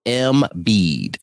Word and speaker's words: Embiid: Back to our Sixers, Joel Embiid. Embiid. Embiid. M- Joel M bead correct. Oh, okay.